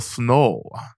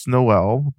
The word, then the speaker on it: SNOELL: What is S-N-O-E-L-L? – So Snow, sometimes pronounced Snowell, but Snowell is only pronounced during the holidays. But those are the two primary pronunciations, but Snowell is just my method of working with sensation and material in my mind and in my body, right?